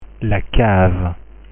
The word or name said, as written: la cave